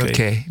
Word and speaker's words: Visual Kei: Do you say, how do you pronounce it? – Visual, Visual Kei.